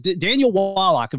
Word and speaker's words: Daniel Wallach: I mean, both you and your partner, I believe Daniel Wallach, am I pronouncing his name correctly? – Yes.